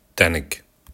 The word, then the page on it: tenik: Kurdish